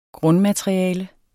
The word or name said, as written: grundmateriale